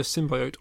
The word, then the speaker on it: symbiote: I believe, I that either, symbiote. I believe either symbiote or symbiote is acceptable.